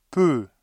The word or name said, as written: peu